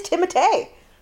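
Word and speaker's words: Timothée: I'm probably not even pronouncing it. It is Timotei.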